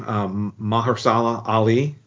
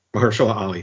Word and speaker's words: Mahershala Ali: Mahershala Ali. – Okay. – Mahershala Ali.